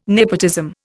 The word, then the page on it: nepotism: Indian